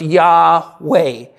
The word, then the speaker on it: Yahweh: And our best guess, and I think a very reasonable transliteration would be that it's pronounced Yahweh.